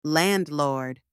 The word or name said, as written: landlord